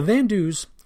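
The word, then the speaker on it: Van Doos: The Van Dues were an all-French-Canadian unit, the name Van Due being a play on the French pronunciation of 22, Van Due.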